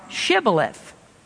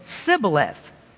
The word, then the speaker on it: Shibboleth: If he said, 'Nay,' then said they unto him, 'Say now, Shibboleth.' And he said, 'Sibboleth,' for he could not frame to pronounce it right."